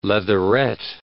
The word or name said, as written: leatherette